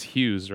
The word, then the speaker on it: Hughes: So, my last name is Hughes, right? – Yeah. But it's spelled H U G H E S. But if you drop the G H, it's just the color, right?